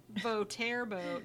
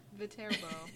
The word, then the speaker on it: Viterbo: Viterbo. No, it's Viterbo.